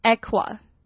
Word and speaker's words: aqua: 那它的名词呢，水就是 aqua， aqua，这发音还蛮特别的， aqua。